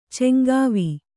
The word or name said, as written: ಚಂಗಾವಿ